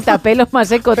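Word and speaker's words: Thapelo Maseko: Tapelo Maseko. – ¡Qué bien lo has dicho! No sé si se dirá así. – No, sí, Tapelo Maseko, tal cual. Lo he leído tal cual se escribe.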